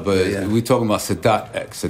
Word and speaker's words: Sadat X: Sadat X. You ever heard of Anwar Sadat? – Yeah, I know him, yeah. – Right, so Sadat X.